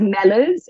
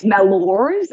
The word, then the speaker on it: Mellors: Well, my surname or last name is not that useful for American, because it's pronounced Mellors in British, but because Americans do that post-vocalic R that's very it's Mellors in American.